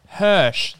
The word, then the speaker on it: Hirsch: From the creators Alex Hitchens and Shion Takauchi. – Isn't it Hirsch? – Hirsch. That's probably how you pronounce it.